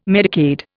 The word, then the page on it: Medicaid: Indian